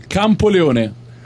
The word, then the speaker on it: Campoleone: That's very interesting that you guys took your city, and I think it's I know the name like Campolanoa. How do you say it? – Campoleone. – Campoleone. Jesus, that was Campoleone, very far from the right the right thing.